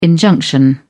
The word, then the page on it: injunction: British English